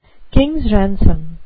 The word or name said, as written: king's ransom